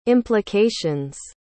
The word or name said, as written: implications